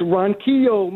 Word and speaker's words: Ronquillo: It's Ronquillo, man, but I could spell it for you.